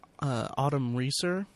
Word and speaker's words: Autumn Reeser: But anyway, his character in the movie is, he meets Autumn Reeser.